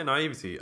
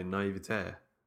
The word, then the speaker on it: naivety: We say naivety. I'm sure I've heard a lot of Americans say naivete. Like they have like the accent on the end. And I've always thought that wasn't right. I always thought it's naivety, surely.